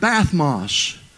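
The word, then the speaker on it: bathmos (βαθμός): Now, the word degree in the Greek is the word bathmos, B-A-T-H. It's like the word bath, dash, mos.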